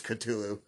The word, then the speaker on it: Cthulhu: I think I'm saying it right, Cthulhu, or is it Chotulu? It's Cthulhu.